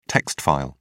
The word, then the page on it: text file: British English